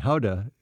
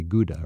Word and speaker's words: Gouda: And Gouda is the properly pronounced word, what we say, Gouda, right? For Gouda cheese. – Yes.